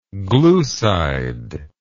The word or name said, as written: gluside